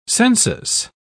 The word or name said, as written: census